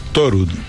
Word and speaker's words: Thorud: Thorud. – Thorud. – Thorud. Como el dios Thor, porque es un apellido noruego.